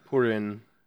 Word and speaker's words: Purin: P-U-R-I-N-H-A-R-U-M-A-K-I? – Yeah, Purin.